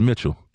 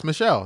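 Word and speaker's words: Machado: Why you call the dude Michelle? His name is Mitchell. – No, it's Michelle. That's how they say it.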